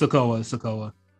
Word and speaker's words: Sikoa: Yes, it's Sikoa. Okay. When they said it on commentary, I was just like, oh, shit, I have been saying it wrong.